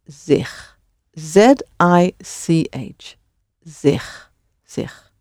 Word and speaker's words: zich: Zich is spelled Z-I-C-H. And that C-H is another way of spelling the G sound. So the G of zich is exactly the same sound as the G in goed or in graag.